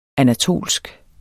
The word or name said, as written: anatolsk